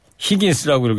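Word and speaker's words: Higgins: 연중 무휴 하루간 라디오 가자 선거 건달 김경래 PD의 첫 곡입니다 버티 하긴스 카사블랑카 하긴스가 아니고 히긴스입니다. 네, 가수 이름을 제가 버티 히긴스라고 읽어야 되는데 하긴스라고 읽어가지고 예.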